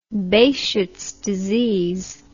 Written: behcets disease

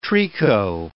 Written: tricot

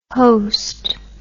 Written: hoast

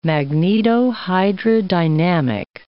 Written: magnetohydrodynamic

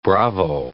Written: bravo

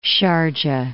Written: Sharjah

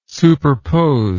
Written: superpose

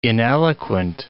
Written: ineloquent

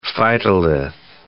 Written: phytolith

